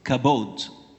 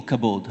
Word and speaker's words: kabod: The basic meaning of the Hebrew word for glory is kabod. I'm not sure if I'm pronouncing it right, but it's K-A-B-O-D when spelled in English.